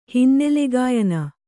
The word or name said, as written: ಹಿನ್ನೆಲೆಗಾಯನ